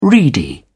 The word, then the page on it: reedy: British English